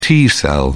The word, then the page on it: t cell: British English